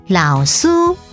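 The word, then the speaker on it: 老師: 老师台语念作老苏，老苏客语念作信桑，信桑。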